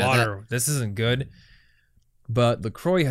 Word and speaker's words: LaCroix: And I think you would actually pronounce it LaCroix.